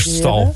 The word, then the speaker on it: kerststal: Is het nou cashcow, cashcall of kerstal? Het kon ik er wel even wat beter uitspreken. – Kerststal. – Het is kerststal.